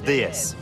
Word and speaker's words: DS: En français, on prononce DS.